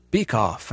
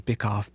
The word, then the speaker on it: Bikoff: Don Bickoff, I believe that's how you say his name. Bickoff, B-I-K-O-F-F.